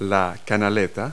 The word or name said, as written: gutter